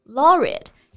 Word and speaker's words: laureate: Laureate 这个单词的发音呢，注意我们后面呢有一个 a t e，但它读音不是吃的那个 aid，而是发一个弱化的 schwa 的元音。